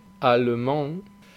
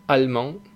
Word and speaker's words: allemand: En este último caso, cuando va en una sílaba intermedia, pocas veces se pronuncia. Yo creo que nadie la pronuncia, nadie dice allemand, a no ser que estés vocalizando muy claramente, pero la gente al hablar rápido ya no la pronuncia, dice allemand.